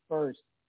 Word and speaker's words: First: It's pronounced first. Like, who's on first? – First.